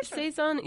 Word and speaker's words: Saison: No, but there's one called, and I'm not going to say it right, Saison? Is it the French word? – Saison, yeah, that's a style of beer, and I think you see several Iowa breweries that are making Saisons.